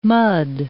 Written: mud

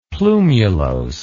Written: plumulose

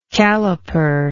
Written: caliperer

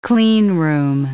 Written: clean room

